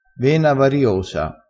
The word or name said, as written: vena